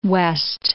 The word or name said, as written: Key West